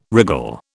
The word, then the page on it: wriggle: British English